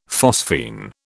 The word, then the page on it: phosphene: British English